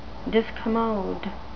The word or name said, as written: discommode